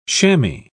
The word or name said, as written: chamois